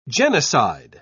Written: genocide

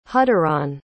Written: hudderon

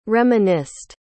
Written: reminisced

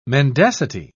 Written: mendacity

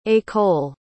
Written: ecole

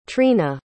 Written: trina

